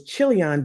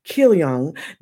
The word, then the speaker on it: Chilion: Ophrah, her husband's name was Chilion. That meant, and it's pronounced actually Kilion.